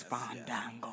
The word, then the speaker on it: Fandango: Yeah, I was ready to say you didn't say his name right. It's Fandango. Yes. Don, Don. You got to make sure you get the Don Don in that thing.